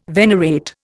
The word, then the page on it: venerate: Indian